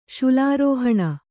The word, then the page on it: ಶುಲಾರೋಹಣ: Kannada